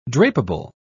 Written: drapable

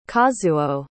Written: kazuo

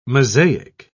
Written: mosaic

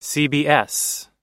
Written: cbs

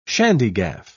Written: shandygaff

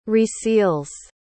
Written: reseals

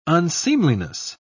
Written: unseemliness

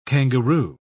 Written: kangaroo

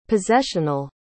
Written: possessional

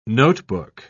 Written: notebook

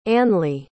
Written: annlea

